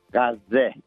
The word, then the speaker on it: Gaza: It's not Gaza. It's Gaza.